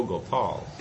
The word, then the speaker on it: Gopal: "O Gopal." The offering of these three syllables, Go, Pa, and La, was his way of properly honoring the Lord.